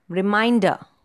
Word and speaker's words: reminder: Next, a three syllable word, reminder. Reminder. So the second syllable again is stressed.